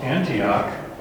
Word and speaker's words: Antioch: And I'm reading this going, oh, it's just because we emphasized the wrong syllable. I mean, you read the word Antioch, and that's how we pronounce it, Antioch, because we have the word anti or anti. But when we put this on it, we pronounce it differently. We pronounce it Antiochus.